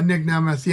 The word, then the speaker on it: Nick Nemeth: For Nick Nemeth. Did I say his name right? – Yeah, Nick Nemeth.